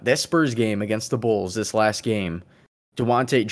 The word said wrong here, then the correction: Dejounte: Dewante Mur- I call him Dewante Murray. Everybody's calling him Dejounte. If his name's Dejounte, I guess I got to say it correctly.